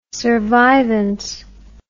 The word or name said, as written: survivance